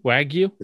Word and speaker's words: Wagyu: How do I pronounce it? Wagyu? Wagyu? It's, yeah, Wagyu.